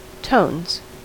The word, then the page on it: tones: American English